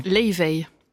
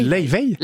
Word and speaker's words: Laufey: Elle est islandaise, Leiveil. – Ça se dit comme ça ? – Ça se dit Leiveil ? – Leiveil. – Ça s'écrit L-A-U-F-E-Y, Leiveil. – Ça fait des mois que je dis faux.